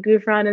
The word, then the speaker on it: Ghufran: Gufron is spelled G-H-U-F-R-A-N.